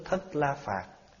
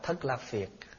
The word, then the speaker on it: Thất La Phạt: Chính tôi được nghe một thời kia Đức Thế Tôn ở vườn của ông Cấp Cô Độc, nước trong thể Đa Lâm, nước Thất La Phạt. Có khi quý vị sẽ đọc là Thất La Phiệt.